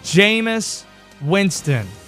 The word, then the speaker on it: Jameis Winston: his name rhymes with famous. It's Jameis Winston.